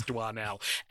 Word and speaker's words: Doinel: this fortnight we were given from our lovely patrons the Adventures of Antoine Doinel, or as we soon found out, Doinel, because of course it's Doinel. Antoine has O-I in it, and we pronounce that fine.